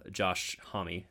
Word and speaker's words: Homme: And I really admire that kind of songwriting, both from Jack White and Josh Homme, which is how I hear it, his name's pronounced now, from Queens of the Stone Age and, mm-hmm.